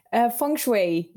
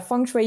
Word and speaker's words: Feng Shui: I am so excited to have you here today, and I am really looking forward to learning all about Feng Shui. Now, is that how you say it? How do you pronounce it? – Feng Shui. Yeah, Feng Shui. You pronounce it very well.